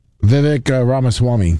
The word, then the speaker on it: Vivek Ramaswamy: Vivek Ramaswamy.